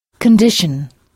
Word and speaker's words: condition: Les mots français en T-I-O-N ont la même terminaison en anglais, mais au lieu de prononcer sion, on prononce shun. Il faut frapper la syllabe qui précède shun. Alors, comment direz-vous condition en anglais? – Condition.